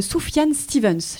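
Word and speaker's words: Sufjan Stevens: Et nous sommes toujours ensemble dans la bulle et nous venons d'écouter grâce à Mika une balade sublime de Soufiane Stevens.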